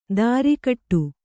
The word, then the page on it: ದಾರಿಕಟ್ಟು: dārikaṭṭu